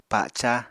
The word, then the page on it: paca: Apatani